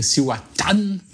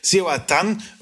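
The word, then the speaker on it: Cihuatán: Cihuatan, gut, da ist so Akzent auf dem A auf dem letzten, deswegen Cihuatan. – Die Betonung ist ganz sicher ganz hin. Also Cihuatan, Ron de El Salvador.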